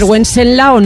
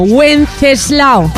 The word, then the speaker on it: Wenceslao: A ver, Wenceslao no, Wenceslao. Ahora lo he dicho bien.